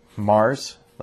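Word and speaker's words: Hmar: And it's spelled H-M-A-R-S. And I think it's Mars. Like you maybe like barely pronounce the H.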